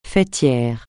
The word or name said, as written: faîtière